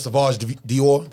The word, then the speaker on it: Sauvage: They ain't buy no fucking Sauvage Dior. Did I say it right? I said it right.